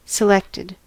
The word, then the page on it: selected: American English